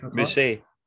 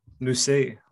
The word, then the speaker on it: Mousset: Mousset. – Mousset. Oh, like Rousset.